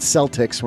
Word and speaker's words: Celtic: Yes, that's how you pronounce that. It's not Celtics. We're not in Boston. It's Celtic.